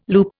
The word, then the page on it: loop: Indian